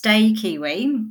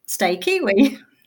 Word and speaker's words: Stakiwi: So it's pronounced Stakiwi because my name's Stakiwi. Kiwi, the national native bird of New Zealand. Stakiwi.